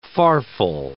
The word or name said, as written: farfel